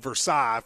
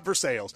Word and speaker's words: Versailles: I know it's spelled Versailles for those of you with – you know, who like to act like you're from France. But in Kentucky, we call it Versailles.